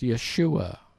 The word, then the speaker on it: Yeshua: Well, it's just pronounced as a name. It's pronounced Yeshua.